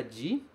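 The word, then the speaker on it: ji (字): Já se você encontra este kanji, por exemplo, ele também se pronuncia ji, mas ele significa caractere.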